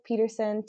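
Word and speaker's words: Petersen: I am on Instagram under Tori Hope Peterson, TORIHOPEPETERSEN, not ON.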